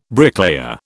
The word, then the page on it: bricklayer: British English